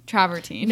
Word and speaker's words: travertine: Like, wait, is your travestine, is that how you say it, coffee table, is it from them? Travertine.